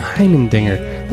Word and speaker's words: Heimendinger: Heimendinger.